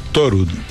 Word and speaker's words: Thorud: Thorud. Como el dios Thor, porque es un apellido noruego.